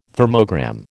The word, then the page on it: thermogram: American English